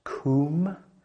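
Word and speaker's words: kum (קום): And although this word arise in this context is when you explore it, it's actually a very soft word that's pronounced kum.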